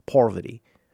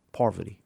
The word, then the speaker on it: Parvati: It also says Parvati. Because in my brain, I also say it like Russell. Say it like Russell says it. – Parvati. – Poverty.